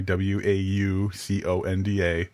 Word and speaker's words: Wauconda: because I I from Wauconda Illinois W A U C O N D A uh, Wauconda, Illinois, which is pronounced the same way as the Wakanda from Black Panther.